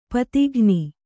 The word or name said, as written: ಪದಗಾಣ್